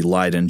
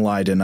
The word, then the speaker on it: Leiden: which is where the Leiden jar gets its name. It's spelled like L-E-Y-D-E-N, but I think Leiden, Holland is with like an E-I. So I'm saying Leiden. I've heard people say Leiden.